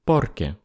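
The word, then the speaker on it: porque: А вот однако ответ на этот вопрос будет «порке», ударение на первый слог «порке».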